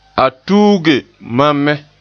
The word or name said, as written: tuuge